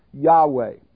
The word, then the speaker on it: Yahweh: The word Lord, when it's in all caps, refers to the covenant name for God, spelled YHWH. We think it's pronounced Yahweh.